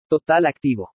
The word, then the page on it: TOTAL ACTIVO: spanish